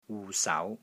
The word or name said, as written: WU6 SAU2